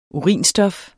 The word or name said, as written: urinstof